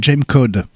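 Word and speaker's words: Gamcod: Et donc là, ils nous ont proposé un single qui s'appelle Jam Code. G-A-M-C-O-D. C'est comme ça qu'ils prononcent.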